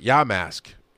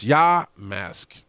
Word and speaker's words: Yamask: I am saying it right. Ya mask. It's Ya mask, is how it says it in the book.